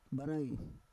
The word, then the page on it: barai: Roon